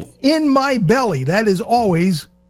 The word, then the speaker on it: poutine: And it says, on the t-shirt, it says P-U-T-I-N. And that's how you say it, put in. And the way you remember it is, put in my belly.